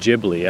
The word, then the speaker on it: Ghibli: Ghibli. I think I said Beyond Ghibli. You know, Studio Ghibli. I don't know why it's a soft G.